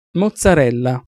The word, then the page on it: mozzarella: Italian